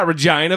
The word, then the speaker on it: Regina: Jen from Regina, the city that rhymes with fun. – I think it rhymes with vagina. – Did I say it right? Yeah, Regina Vagina.